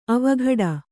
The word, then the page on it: ಅವಘಡ: avaghaḍa